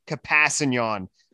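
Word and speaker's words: Kpassagnon: The K does exist. – No. They're saying it wrong. – They're saying it wrong. Tano Capassignon is what his mother prefers.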